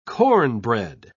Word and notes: cornbread: British English

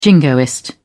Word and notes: jingoist: British English

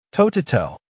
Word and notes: toe to toe: American English

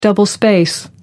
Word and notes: double space: American English

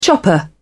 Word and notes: chopper: British English

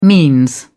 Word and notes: means: British English

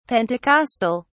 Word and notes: pentecostal: British English

